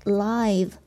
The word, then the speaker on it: live: 那当它作为形容词的时候呢，它表示活着的，这个时候我们一定要注意它的读音，叫做 live。